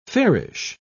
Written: fairish

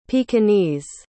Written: pekingese

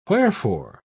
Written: wherefore

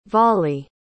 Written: vollie

